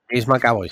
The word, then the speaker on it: James McAvoy: ¿Es James McAvoy? ¿Lo he pronunciado bien? Sí, James McAvoy, sí.